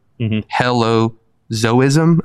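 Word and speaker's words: hylozoism: So animism, helozoism, did I pronounce that correctly? – Correct.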